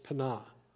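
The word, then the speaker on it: Peninnah: The name of one was Hannah, and the name of the other was Penah." That's how you pronounce it, actually, in the Hebrew.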